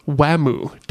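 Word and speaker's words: Wamuu: They keep trying to write it out as Whamu, W-A-M-U. But that is how... – It's how it's said. But yeah. But that's only because of the transliteration of Wham! They're saying Wham! – Yeah. – In Japanese.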